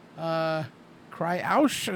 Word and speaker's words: Kyriacou: And the lead singer of the band, Andy Kryaush, is I believe how you pronounce it. He is Russian, I believe. – Uh-huh. – K-Y-R-I-A-C-O-U, said, I've heard a lot about the Rock and Comedy Show.